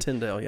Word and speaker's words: Tyndale: I remember the William Tyndale, I think is how you say his name. – Yeah, Tyndale, yeah.